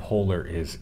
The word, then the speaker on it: Polaris: Not Polar is Insight. Polaris, right? – Yeah, yes. We have different pronunciation. It's like the star, like on your shirt.